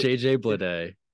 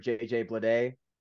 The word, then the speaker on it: JJ Bleday: Is it JJ Bleday? – Okay. They acquired left-hand pitcher AJ Puck from Oakland in exchange for JJ Bleday.